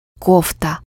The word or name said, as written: cardigan